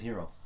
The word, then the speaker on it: Hiro: No, Hiro. No. His name is Hero. – No, yeah, but play on words. – I know, I am Hero. Hero is spelled Hiro.